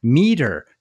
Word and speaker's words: Meater: That is absolutely true, especially this first one, Meter. That's a great play on words because it's spelled M-E-A-T-E-R.